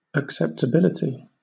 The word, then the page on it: acceptability: British English